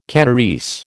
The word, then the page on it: Kanarese: American English